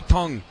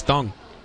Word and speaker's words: Teng: Ahead of the 0-2 from Kai Wei Tung, spelled T-E-N-G, but the Taiwanese pronunciation is Tung.